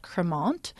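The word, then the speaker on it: Crémant: it's called Cremant, and that's spelled C-R-E-M-A-N-T.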